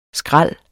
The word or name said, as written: skral